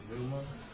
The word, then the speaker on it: pneuma: That's pronounced pneuma. – Pneuma. Pneuma. We get a word pneumatic out of that.